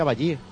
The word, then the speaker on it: Bailly: Bailly, me han dicho que se pronuncia Bailly, de Costa de Marfil.